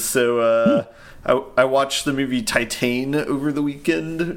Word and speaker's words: Titane: It's spelled the same as Titanic, but because it's French, you say it Titane.